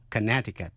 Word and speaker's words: Connecticut: Tiểu bang Connecticut có chữ C ở giữa, câm và đọc là Connecticut, chứ không đọc Connecticut.